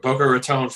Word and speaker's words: Boca Raton: Boca Raton, Florida.